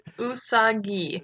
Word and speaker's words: Usagi: Where Usagi, I think that's pronounced right. – Usagi.